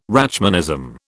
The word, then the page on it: Rachmanism: British English